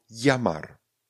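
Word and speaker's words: llamar: Dvěl, pokud se objeví dvěl, vyslovíme jako l, pardon, jako j. Někteří lingvisté tvrdí, že se vyslovují jako j. ale my pamatujme, vyslovujme to jako j. Ano, třeba slovo jamar. Jamar. Dvě na začátku a pak je normálně Amar.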